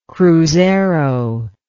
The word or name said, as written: cruzeiro